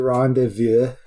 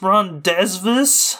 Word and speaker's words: rendezvous: She has arranged a rendezvous with Jason. – And they meet up. It's pronounced rendezvous.